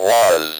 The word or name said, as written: was